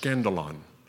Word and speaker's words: scandalon: The word offense in the Greek here is pronounced scandalon, and it actually comes from a root word.